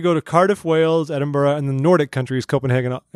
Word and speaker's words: Edinburgh: It's pronounced Edinburgh.